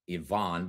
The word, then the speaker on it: Ivan: My middle name is pronounced Yvonne, but it's spelled just like Ivan. Dr. D. Yvonne Young.